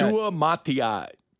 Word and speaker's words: Suamataia: Kingsley Suamataia. I just butchered his name again. I'll get it down one of these days. Suamataia? – I think that's it's. Right. I mean, yeah. – Suamataia.